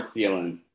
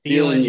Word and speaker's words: Thielen: Tylen, your young ho is Kevin's, and that is you got to pretend it's pronounced more Thielen. Thielen, your young ho.